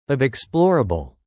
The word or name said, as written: of explorable